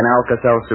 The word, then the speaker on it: Alka-Seltzer: Just remember that A-L-K-A-S-E-L-T-Z-E-R spells relief, although it's pronounced Alka-Seltzer.